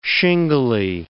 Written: shingly

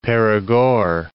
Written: Perigord

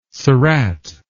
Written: surratt

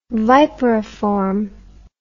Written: viperiform

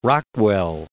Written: Rockwell

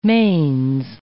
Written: mains

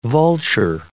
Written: vulture

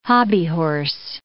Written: hobbyhorse

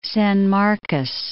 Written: San Marcos

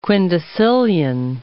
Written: quindecillion

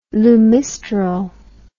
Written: lumisterol

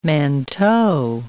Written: manteau

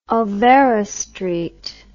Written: Olvera Street

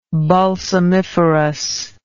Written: balsamiferous

